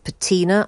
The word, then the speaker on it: patina: So isn't that a rare talent to be able to take on that kind of patina? I always say that word wrong. Have I said it wrong there now already? – I think you said it perfectly.